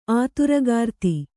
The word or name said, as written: ಅಧರೀಕೃತ